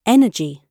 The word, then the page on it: energy: British English